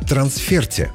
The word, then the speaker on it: трансфер: Словарь трудностей русского языка допускает обе нормы – трансфер и трансферт, но обязательно с ударением на последний слог.